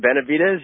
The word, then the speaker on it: Benavidez: But let's talk about how you got involved with this story of Roy Benavidez, if I'm pronouncing that correctly. My wife says I can't pronounce anything, so. – You know what, Benavidez, you got it.